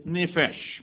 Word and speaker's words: nephesh: Now the Hebrew word here for breath is nephesh. We'll talk about that word quite a bit today. It's spelled N-E-P-H-E-S-H.